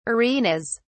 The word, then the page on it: arenas: British English